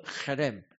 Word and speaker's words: cherem: It's the Hebrew word cherem, right? You must pronounce with the ch sound. Right, Singaporeans a bit hard, right? We are not used to it. Right? It's not harem, it's harem.